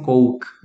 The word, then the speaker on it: Coke: Coke. C-O-K-E. Coke. Não é pra falar có, não, tá bom? É coke. Se você falar o có, vai parecer aquela palavra que eu falei.